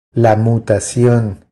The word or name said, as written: La mutacion